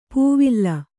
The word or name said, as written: ಪವಳ